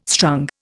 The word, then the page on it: strung: Indian